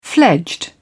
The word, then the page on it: fledged: British English